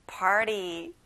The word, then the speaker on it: party: T 발음은 잘 안 나요. 우리 한번 굴려서 말해볼까요? Party.